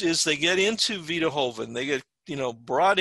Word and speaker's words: Joven: But the ones that I actively give to include something called Vita Hoven, which is spelled J-O-V-E-N. It's pronounced Hoven, though, because it's Spanish.